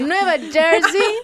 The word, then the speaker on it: Nueva Jersey: Había que pronunciarlo así. Nueva Jersey.